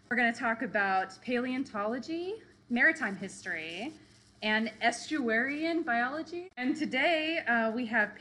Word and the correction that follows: estuarine: Did I say that right? Estuarian? – Estuarine.